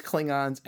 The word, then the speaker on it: Klingon: Uh, Klingon is actually how Roddenberry wanted it pronounced. People kept oh. saying, uh, elongating the A and saying Klingons, and that just stuck after a while.